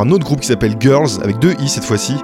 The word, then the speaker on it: Gyrls: Et là, tu vas nous parler de Girls. Alors, ça s'écrit G-Y-R-L-S.